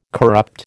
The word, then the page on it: corrupt: American English